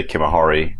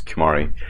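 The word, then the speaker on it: Kimahri: I'm not mispronouncing it, it really does say Kimahari, not Kimari.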